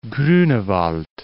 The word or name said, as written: Grunewald